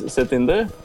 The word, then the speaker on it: Satinder: My first name is Satinder. S A T I N D E R.